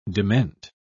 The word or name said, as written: dement